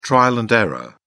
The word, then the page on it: trial-and-error: British English